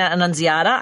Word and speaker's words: Annunziata: We also have Dr. Christina Annunziata.